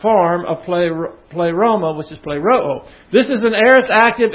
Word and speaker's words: pleroo: P-L-E-R-O-O. I'm just going along with the crowd on this. Actually, it would be pronounced pleroo. Because you have an omicron and then an omega here. See, this is an omicron, pronounced all. And the omega pronounced O. So this would be play Rao. But I don't ever hear any. Have you ever heard anybody pronounce it that way?